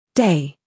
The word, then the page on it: day: British English